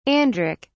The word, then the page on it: andric: British English